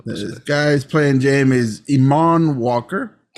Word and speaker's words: Eamonn: Did I say it right? I don't know if I said it right. – Spell it. – E-A-M-O-N-N. – Yes. – I did? – Yeah, sure.